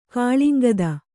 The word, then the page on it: ಕಾಳಿಂಗದ: kāḷiŋgada